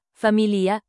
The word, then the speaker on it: famiglia: Se pronunce, pit tsa, avec double z qui fight, ts, famiglia, famille, se pronounce, familia, gl più I fight le son, li,